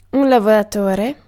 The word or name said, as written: working